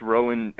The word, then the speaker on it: Rohan: You know, either way, it's pronounced Rowan as if the H were a W.